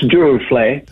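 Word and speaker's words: Duruflé: And according to Michael Linton, the best liturgical music for the Mass, and you might need to correct me here, since Durufle, did I pronounce that right? – It's close, Durufle.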